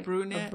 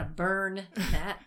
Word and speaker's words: brunette: And uh, it didn't sound like I was saying it right. – Brunette. A brunette. – Uh-huh. Yeah. And she, yeah, not a burn net, brunette.